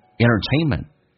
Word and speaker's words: entertainment: Entertainment 这个单词 nt 组合，经常你会发现 t 有时候可以不用发音，在口语化当中经常偷懒说成 entertainment， enter。 T 直接去掉 entertainment 就好像 international 一样，变成 international internet。